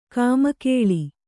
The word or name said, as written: ಕಮ್ಮಗೋಲ್